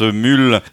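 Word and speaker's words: Mhaol: Ça s'écrit Maol avec un H, mais visiblement ça se prononce Mule.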